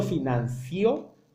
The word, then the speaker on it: financio: lleva el acento en la I. – Sí. – Siempre. No se dice eso, se dice yo financio, tú financias, él financia, nosotros financiamos, ustedes financian, ellos financian.